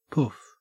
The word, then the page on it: poof: Australian English